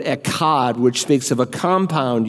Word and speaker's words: echad: Interestingly, when the Bible says God is one, as in the Shema, like we just read in Deuteronomy 6:1, the Hebrew word for one is, we pronounce in English, eshad. They'd say echad, echad, which most literally is used to speak of a compound unity.